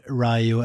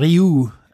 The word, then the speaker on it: Ryu: hur man uttalar Ryo är egentligen Ryu, eller Rio, eller hur man nu säger. Ryu. – Ja, precis. Och det, snubben som spelar Ken, anledningen till att de valde Ryo i filmen, det är liksom fel uttal.